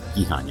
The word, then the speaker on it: Gihon: Gihon, yes.